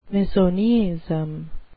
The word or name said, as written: misoneism